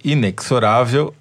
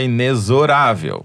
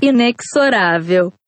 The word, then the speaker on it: inexorável: No programa passado, a Yara Ruda nos ensinou, ou melhor, me ensinou, que inexorável é na casa da mãe Joana, que o certo é inexorável. Mas teve muita gente que surtou com essa informação. E lá nos comentários do YouTube, por exemplo, o Alexandre Bonfim protestou. Mas até a moça do Google pronuncia inexorável. – Inexorável. – Pois é, Alexandre, tá todo mundo errado. Precisa avisar o Google, porque é inexorável mesmo.